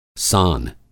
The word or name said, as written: सान